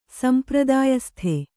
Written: ಸಂಪ್ರದಾಯಸ್ಥ

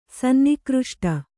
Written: ಸನ್ನಿಘೃಷ್ಟ

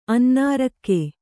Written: ಅನ್ನಾರಕ್ಕೆ

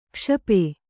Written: ಕ್ಷಪೆ

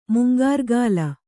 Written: ಮುಂಗಾರ್ಗಾಲ

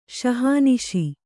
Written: ಶಹಾನಿಶಿ

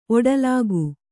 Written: ಒಟ್ಟುಲೆಕ್ಕ

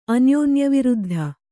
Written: ಅನ್ಯೋನ್ಯವಿರುದ್ಧ

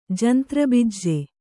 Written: ಜಂತ್ರಬಿಜ್ಜೆ

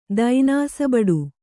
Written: ದೈನಾಸಬಡು

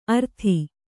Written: ಅರ್ತಿ

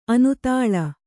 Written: ಆನೆದಲೆ

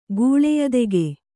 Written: ಗೂಳೆಯದೆಗೆ

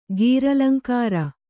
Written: ಗೀರಲಂಕಾರ

